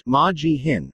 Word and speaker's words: McGeehan: Among the five Republican officials who spoke was McGeehan, pronounced Ma-Gee-Hin, Idaho's lieutenant governor.